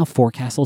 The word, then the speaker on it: forecastle: Yeah, like that is what the forecastle, which is how forecastle is pronounced.